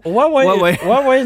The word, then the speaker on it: Huawei: Oui, je l'ai mal prononcé. – Oui, mais c'est correct. – J'ai dit Huawei. – Oui, j'ai fait comme si de rien n'était, bon. Mais si tu voulais qu'on le souligne, tu l'as mal prononcé.